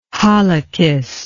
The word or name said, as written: halakhist